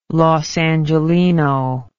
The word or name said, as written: los angeleno